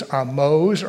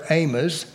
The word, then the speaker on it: Amoz: was Amoz or Amos, however you want to pronounce it.